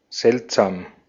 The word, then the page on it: seltsam: /ˈzɛltˌzaːm/